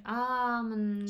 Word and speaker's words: almond: Is the L pronounced an almond? Usually silent. – All right. That's unfair. You shouldn't silent the letter L. – It lengthens the previous vowel sound. Almond.